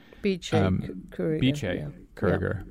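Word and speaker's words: Bice Curiger: Beche Kuriger.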